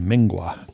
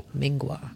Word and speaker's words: MNGWA: And we're going to go see a little band called Mingwa. – Mingwa. – Yeah. It's spelled all caps MNGWA.